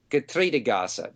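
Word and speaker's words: Getreidegasse: number 9 Gertrudegasse, Gaza simply means a narrow street. So Getreide Gasse, that's probably better.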